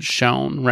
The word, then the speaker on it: Schon: Carrie, I think you and I don't know for sure, but we both agreed we would pronounce it Schon, right? – Sure.